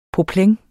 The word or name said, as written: poplin